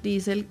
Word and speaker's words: Diesel: Diesel, eso ya se los había contado a ustedes aquí, viene del nombre de Rudolf Diesel, el ingeniero alemán que inventó el motor diesel a finales del siglo XIX. Y en alemán se pronuncia diesel como con una I larga.